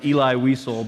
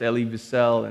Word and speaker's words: Elie Wiesel: His name is, it looks like it's pronounced Eli Wiesel, but it's pronounced Elie Wiesel.